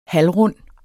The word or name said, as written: HALVRUND